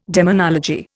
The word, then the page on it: demonology: Indian